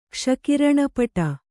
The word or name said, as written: ಕ್ಷಕಿರಣಪಟ